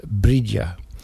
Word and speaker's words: Bride: Bride, fair white, in the first stanza refers to Saint Bride, pronounced Bridia, and the foster mother of Christ, and a sort of patron saint of the women of the islands.